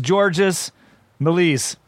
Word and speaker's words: Georges Méliès: The first depictions of supernatural events appear in several of the silent shorts created by film pioneer Jorge Mele, or however you want to. It's Georges Méliès.